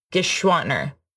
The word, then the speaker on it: Geschwantner: That's how you say it? – Yes. – Beautiful. Okay. Geschwantner. – Geschwantner. Yes. – Okay. Two syllables.